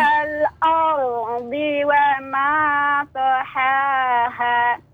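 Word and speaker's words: wal ardi (الأرض): Al bacanya aldi, di bukan terdengar huruf dal. Kadang do sama dal itu cara bacanya hampir sama kayak wal ardi. – Iya yeah, betul. – Ha-ha. Betul. Ha. Do di sini nih wal aldi, di, di. Nah, bukan wal aldi.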